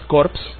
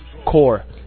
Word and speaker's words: Corps: Entonces la pronunciación no es Corps, que sería cadáver, la pronunciación es Corps. Aunque se escribe C-O-R-P-S, se pronuncia C-O-R-E, ¿no?